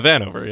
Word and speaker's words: Connor Vanover: Am I saying that right? Connor Vanover? – Yeah, Vanover, yeah.